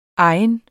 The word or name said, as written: egen